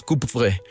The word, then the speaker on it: Coupvray: ¿Ahí en Coupvray se pronuncia así? – Sí, exactamente. Se pronuncia más o menos Coupvray.